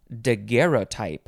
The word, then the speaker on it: daguerreotype: Next is daguerreotype. You can pronounce this a couple ways. Daguerreotype, daguerreotype.